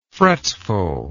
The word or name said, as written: fretfulness